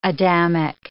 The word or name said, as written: Adamic